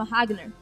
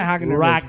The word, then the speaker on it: Ragnar: Só que aí o cara fodão, que se chama Ragnar, ou você quer ajudar na pronúncia, não sei. – Não é Ragnar. – Ragnar. – Isso, não, quer dizer. Aí é o americano que tá errado.